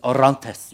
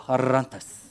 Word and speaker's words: Orantes: And I was trying to figure out how to say his last name, and I asked someone, it's Orantes. You got to roll the R. Roberto Orantes.